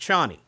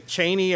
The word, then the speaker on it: Chani: And also too, cause Dwight and I did, again, we had conversations prior to this in this particular, incarnation of Dune, Zendaya's character's name is pronounced Chani. – Mm-hmm. – Right. Mm-hmm. But you said a Chaney, or people were unsure as to exactly what the pronunciation was supposed to be. – Yeah, the British audio representation I heard of it, they call it Chaney.